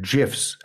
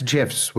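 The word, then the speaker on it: GIF: For my money, the guy who's posting GIFs, and that's GIFs with a soft J sound, not GIFs, all of you misspeakers out there.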